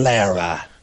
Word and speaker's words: Lara: I don't pronounce it correctly. – Lara. Lara Croft.